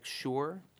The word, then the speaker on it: Shura: It's Islamic Shura? – Shura, S-H-U-R-A.